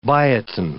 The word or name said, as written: biotin